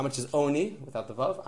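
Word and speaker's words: Oni (עני): And it's spelled, Oni is spelled like Ani. It's pronounced Oni in the Torah, but it's spelled Ani, Lechem Ani.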